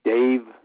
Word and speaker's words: Dave: Dave, D-A-V-E, Bowers, B-O-W-E-R-S. – Could you spell that last name for me? – B-O-W-E-R-S.